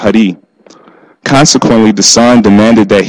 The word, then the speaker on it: Hari: The robber was actually a well-known criminal whose name was often mispronounced Harry. His name was actually pronounced Hari, which means he who removes all obstacles to spiritual progress.